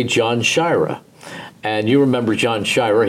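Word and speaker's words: Sciarra: And I said, how would you like your name pronounced? And he said, John Shira. And from that point on, he was always John Shira with me, but Dick would still refer to him, and a lot of people would call him John Shira.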